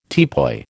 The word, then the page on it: teapoy: American English